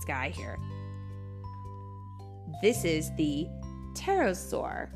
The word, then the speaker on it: pterosaur: The crazy thing about the pterosaur is that there is a big old P right at the very front of the word, but you don't pronounce it.